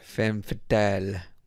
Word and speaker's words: femme fatale: Let's just say she's like the femme fatale. I don't know how to say it. – Femme fatale, which means she's an evil bitch.